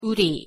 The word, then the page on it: 우리: Korean